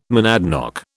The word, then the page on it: monadnock: British English